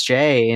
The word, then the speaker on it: Jay: My name is Jay and it's spelled J-A-Y for posterity's sake.